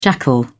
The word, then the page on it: jackal: British English